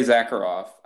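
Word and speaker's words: Sakharov: Andrei Zakharov, spelled with an S, but definitely has that Z sound, right?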